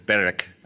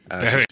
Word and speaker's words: Berwick: Well, if you ask my great-grandmother, it's Berwick. Berwick. She's from Scotland, but I just say Berwick.